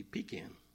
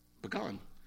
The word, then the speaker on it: pecan: I mean, if you grew up in Georgia, how would you pronounce it? It'd be pecan. Here, it's pecan, you know.